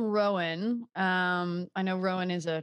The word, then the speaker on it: Rowan: So my mom and all of her sisters are from Rowan County, North Carolina. And there it is definitely Rowan. – Rowan. Okay. – But Rowan is how we pronounce Rowan.